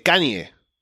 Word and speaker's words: Kanye: ¿Cómo se pronuncia Kanye West? Cuéntame. – Es Kanye. Es como Kanye. Kanye West.